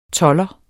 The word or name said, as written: tolder